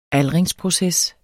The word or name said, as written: aldringsproces